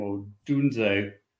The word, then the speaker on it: Odunze: Odunze.